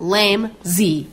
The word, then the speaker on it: lamsey: The definition of the word lamsey is a word describing anyone who is the combination of lame and lazy, pronounced lame-zee.